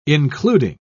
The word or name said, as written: including